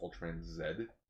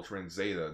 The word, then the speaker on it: Ultraman Z: Okay, so according to my sister, it will be pronounced Ultraman Zed. – Okay, yeah. So, the Canadian version way of saying letter Z. – Because at first I was like, oh, Ultraman Zeta.